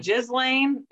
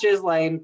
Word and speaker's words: Ghislaine: How do you say her name? – Ghislaine. It's not Ghislaine, but it should be.